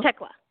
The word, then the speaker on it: Thekla: Thekla. The H is silent.